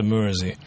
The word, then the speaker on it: Mersey: Mersey — это по названию реки Мерсей. Так и пишется Мерсей. Латинскими только буквами читается Mersey.